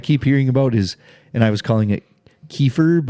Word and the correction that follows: kefir: but apparently it's pronounced kefir, but it's like a...